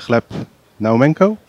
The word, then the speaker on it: Gleb Naumenko: Zo is eh, Bitcoin-onderzoeker Gleb Naumenko, spreek je dat zo uit, Wessel? – Ja.